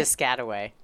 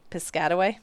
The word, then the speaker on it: Piscataway: How do you say? – Piscataway. – Piscataway? – Yeah.